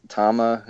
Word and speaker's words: Tama: It's an old, Tama is how you pronounce it.